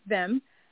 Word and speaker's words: THYIM: Yes, we are actually going to open the market over the year, and it's pronounced them, THYIM, them, like them.